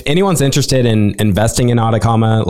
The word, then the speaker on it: Atakama: And Atacama is spelled A-T-A-K-A-M-A. We're named after the Atacama Desert in Chile, which is spelled with a C. But we spell our name with a K.